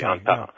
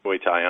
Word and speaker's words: Muay Thai: There's a lot going on here, including a physically demanding job, P90X, which is a higher volume and frequency-oriented program, and Muay Thai. I don't know if I'm pronouncing that correctly. – Muay Thai, Muay Thai on top of that.